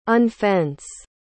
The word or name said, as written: unfence